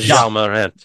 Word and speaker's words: Ja Morant: Would you like me to say it? Ja? Ja. – Yeah. – Is that how you pronounce his name? – Yeah. – Ja Morant.